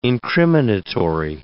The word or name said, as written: incriminatory